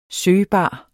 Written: søgbar

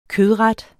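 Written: kødret

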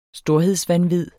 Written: storhedsvanvid

